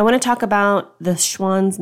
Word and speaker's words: Schwan's: Schwan.